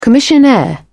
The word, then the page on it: commissionaire: British English